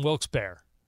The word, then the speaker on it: Wilkes-Barre: What is the exact pronunciation? Because it's B-A-R-E, correct? – Let's ask our Pennsylvania native what he says. – So I always said Scranton Wilkes-Barre.